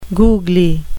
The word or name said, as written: googly